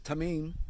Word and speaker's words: tamim: Matter of fact, the word that's often translated from the Hebrew into English as perfect is a Hebrew word called, I'm going to pronounce it, I'm probably going to butcher it, tamim, but it's spelled T-A-M-I-M. So you can Google that, T-A-M-I-M, and look into it.